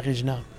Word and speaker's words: Regina: Regina, en anglais, ça prononce Regina.